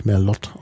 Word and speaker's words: Merlot: Merlot zum Beispiel, der übrigens falsch ausgesprochen wird. Da müsste man eigentlich Merlot aussprechen. Tatsächlich. – Ja. Naja, Französisch ist Merlot schon richtig, nur es ist kein französisches Wort. Es ist okzitanisch.